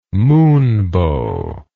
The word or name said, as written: moonbow